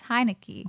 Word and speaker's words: Heinicke: My honorable is Taylor Heinicke. I want to make sure I said it right because his last name's hilarious. Heinicke. – Oh, Heineken. – Heineken? – Heinicke.